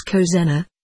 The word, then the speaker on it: Cosena: She told him that her colleague's name was Cosena, the second syllable stressed.